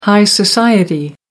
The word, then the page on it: high society: American English